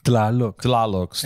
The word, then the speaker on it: Tlaloc: Tlaloc.